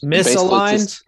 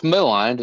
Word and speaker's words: maligned: Pronounce that word. What is that word? – Maligned. – Maligned. – What does that mean? – I have no idea. – It's just misaligned. – No, it's maligned.